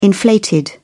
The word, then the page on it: inflated: British English